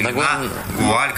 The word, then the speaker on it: нагуаль: Нагуаль или нагуаль, как оно ударение делается? – Не, ну нагуаль на третий слог, как бы, мы просто для графической красоты выделяем букву У.